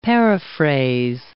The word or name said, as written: paraphrase